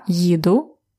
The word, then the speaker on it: їду: And be careful to pronounce yi very well. Uh, there are two sounds there. It's y and i. Because another verb, іти, я іду, ти ідеш, has no y and it means I walk.